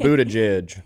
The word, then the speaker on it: Buttigieg: Buttigieg. – Buttigieg? – Pretty sure on that. – Man, you got to change that. – Buttigieg.